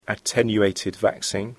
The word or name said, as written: attenuated vaccine